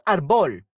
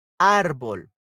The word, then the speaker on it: árbol: so it's not arbol. – Okay, it doesn't have the tilde the last syllable because it doesn't end with N, S, or vowel. It ends with L. So we have we it does have a tilde, but it's before the bow. It will be with R. Árbol.